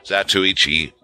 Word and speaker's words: Zatoichi: Zatoichi films and Kurosawa films and The Swordsman.